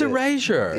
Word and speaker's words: Erasure: It's Erasure. – Yeah, either way, wrong. So there's two wrong people, one right. Yeah. – Apparently, Neil and I didn't know that. Yeah, I thought it was Eraser.